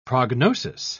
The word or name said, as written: prognosis